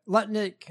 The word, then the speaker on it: Lutnick: Lutnick, I said it right.